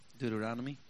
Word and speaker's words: Deuteronomy: I cannot pronounce that textbook. – Deuteronomy. – Yes, Deuteronomy 4:35.